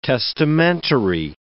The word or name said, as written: testamentary